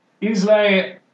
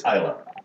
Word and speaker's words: Islay: Oddbag, Auriverdes, the ultimate, Isle single. I always want to pronounce it that way. – Ile. – It's tech. Uh, I have been told by natives it's Islay.